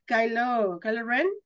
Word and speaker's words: Kylo Ren: El delgadito es el que sale en las películas de Star Wars, ya donde sale Kylo Ren.